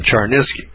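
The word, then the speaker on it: Cherniske: It's spelled Stephen Char, C-H-E-R, Niske, N-I-S-K-E. Stephen Charnisky.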